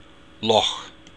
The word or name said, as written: loch